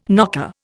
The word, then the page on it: knocker: Indian